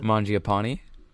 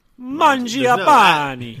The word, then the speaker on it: Mangiapane: Mangiapane. – Mangiapane. – No, yeah, he's not Italian. There's no accent.